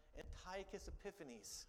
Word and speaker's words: Antiochus Epiphanes: Remember, I'm going to say his name correct this time, Antiochus Epiphanes.